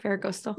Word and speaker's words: Ferragosto: I consider that a bonus because I feel like you taught us Ferragosto and that, did I say that right? – Yeah. – Ferragosto.